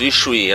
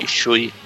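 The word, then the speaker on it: issue: Isso, Ishui, é assim que se pronuncia mesmo. Ishui. Claro que é Ishui.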